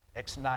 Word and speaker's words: ex nihilo: Ex nihilo. – Ex nihilo.